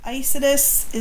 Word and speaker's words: Isatis: Isidus is I-S-A-T-I-S.